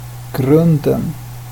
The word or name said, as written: grunden